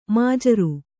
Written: ಮಜೂರ

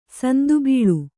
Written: ಸಂದುಬಳ್ಳಿ

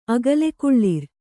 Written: ಅಗಲೆಕುಳ್ಳಿರ್

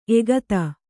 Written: ಏಕತ

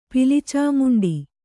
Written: ಪಿಲಿಚಾಮುಂಡಿ

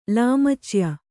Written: ಲಾಮಚ್ಯ

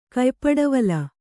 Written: ಕಯ್ಪಡವಲ

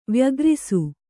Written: ವ್ಯಗ್ರಿಸು

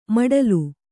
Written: ಮೊಟಲೆ

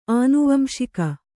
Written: ಆನುವಂಶಿಕ

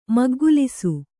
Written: ಮುಕ್ಕುಗೆಲಸ

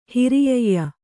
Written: ಹಿರಿಯಯ್ಯ

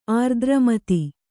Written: ಆರ್ದ್ರಮತಿ

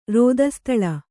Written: ರೋದಸ್ತಳ

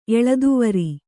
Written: ಎಳದುವರಿ

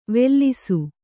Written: ವೆಲ್ಲಿಸು

